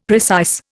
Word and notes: precise: Indian

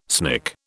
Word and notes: snick: British English

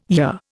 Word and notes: year: Indian

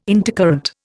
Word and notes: intercurrent: Indian

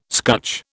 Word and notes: scutch: British English

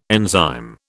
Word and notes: enzyme: British English

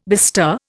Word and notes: bestir: Indian

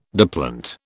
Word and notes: diplont: British English

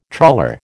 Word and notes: trawler: American English

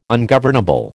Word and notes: ungovernable: American English

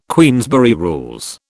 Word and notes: Queensberry Rules: British English

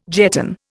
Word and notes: jetton: Indian

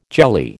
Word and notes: jelly: American English

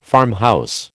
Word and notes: farmhouse: American English

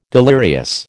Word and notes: delirious: American English

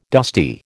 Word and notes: dusty: American English

